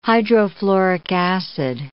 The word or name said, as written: hydrofluoric acid